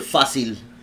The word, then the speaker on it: Fácil: Fácil.